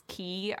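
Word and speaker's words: Key (Kingdom Hearts χ): Plus, then it also has like a little movie called Kingdom Hearts Key. I think it's pronounced Key. It's C-H-I.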